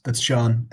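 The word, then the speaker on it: Seán: Yeah, that's Sean. The A is the that's how you spell it in Irish.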